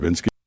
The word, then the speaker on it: Trubisky: You will probably need to self-medicate in order to survive the Mitch Trubisky Bowl. We just heard Trubisky.